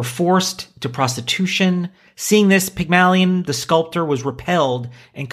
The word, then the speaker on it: Pygmalion: But it starts with, in ancient Greek mythology, Pygmalion, perfect. Is that, am I saying that right? – Yep.